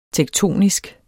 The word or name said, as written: tektonisk